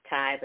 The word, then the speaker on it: Ty: My name is, it looks like Tia, and I answer to Tia. It's actually pronounced Ty, but that's okay if you say Tia, no worries.